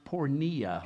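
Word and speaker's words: pornea (πορνεία): And what heads up the list is in the Greek what is pronounced pornea, where we get the word pornography.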